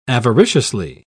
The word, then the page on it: avariciously: British English